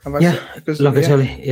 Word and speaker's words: Locatelli: This week's rumour says, the latest one is Locatelli. Is that how you pronounce his name? – Locatelli, yeah.